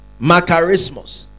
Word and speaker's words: makarismos: Makarismos. Say makarismos. It is spelled M-A-K-A-R-I-S-M-O-S. M-A-K-A-R-I-S-M-O-S.